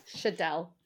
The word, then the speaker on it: Schaedel: Schaedel.